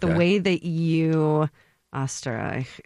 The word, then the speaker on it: Österreich: And in Austrian, pronounced Österreich, which I did hear someone at some point say, no, that's ostrich.